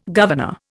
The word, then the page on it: governor: Indian